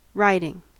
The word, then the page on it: riding: American English